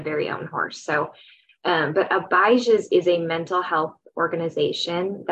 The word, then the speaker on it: Abijah: Yeah, it's Abijah's. – Abijah, long I.